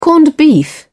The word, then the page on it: corned beef: British English